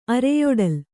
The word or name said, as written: ಅರೆಯೊಡಲು